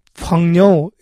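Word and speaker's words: 펑녀 (朋友): 예, 그거를 중국 발음으로 펑녀 이렇게 발음합니다.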